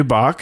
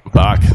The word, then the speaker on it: Bach: And that expert is Matthew Bach from Puget Systems. Did I say your last name there right, Matthew? – Yeah, that is correct. Yep, it's Bach. – Bach.